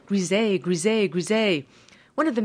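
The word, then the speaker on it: Griesa: Judge Thomas P. Grisey, or Gresa, as it's pronounced in Spanish.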